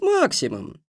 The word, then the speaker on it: Максимом: — С Максимом, — поправил старик. — Ударение на первом слоге.